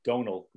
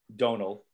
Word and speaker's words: Domhnall: I believe the pronunciation is Donal Gleason. Donal. I don't know why. Weird. It's spelled D-O-M-H. Right. Donal. Those of you playing at home, Donal.